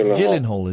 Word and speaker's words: Gyllenhaal: It's a soft G. It's Gyllenhaal. – Oh, I don't know. – Jake Gyllenhaal, isn't it? – Have you seen Enemy?